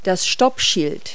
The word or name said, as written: stop sign